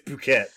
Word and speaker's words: Phuket: I assume this is actually how it's pronounced, but Phuket is spelled fuck it. And that's why I know about Fuck It Thailand. – Of course. – Because it's spelled like that. That's the only reason I know about it. I would assume that's also why Sawyer knows about it, because it's funny. Because it's a place that's spelled like Fuck It Thailand. So. – Sure, maybe. – But apparently it's Phuket, so.